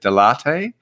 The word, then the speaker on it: Delatte: If I got this right, sounds like a coffee, but Delatte? – Yeah.